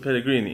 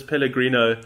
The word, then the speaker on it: Pellegrini: The funniest part about that is that Mourinho now, for the third time, has intentionally mispronounced his name. So whenever, because people keep asking Mourinho about what did you think of his comments, and he goes, "Oh, this Pellegrino."